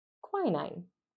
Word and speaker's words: quinine: is quinine. – Great job on your pronunciation on this question. There are a lot of words I would mispronounce, including the answer.